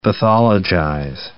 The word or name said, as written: pathologize